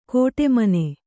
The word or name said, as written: ಕೋಟೆಮನೆ